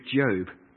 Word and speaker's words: Job: it is a good Job. So rhymes with robe, okay?